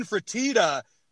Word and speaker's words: Fertitta: Well, you talked about Mickey Arison, which is a perfect example, but I'm going to bring up another one, and you constantly mispronounce his last name. It's Tillman Fertitta, but a lot of his money is tied up in shit that is not making money right now.